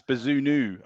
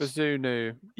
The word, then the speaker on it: Bazunu: Bazooni. – I'll give it to you. It's Bazunu, I think it is pronounced. – Bazunu.